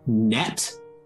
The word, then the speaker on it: Nut: but it is spelled N-U-T, like the word nut. Because in original Egyptian, they didn't really do vowels, so it was just N-T. – Like a nut? – Yeah, it was just nut. So we don't really know how it's supposed to be pronounced, but I'm going to say net because I don't want to constantly be saying like nut.